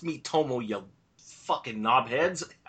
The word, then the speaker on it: Miitomo: But we did talk about Miitomo, which I was calling Miboto, last week.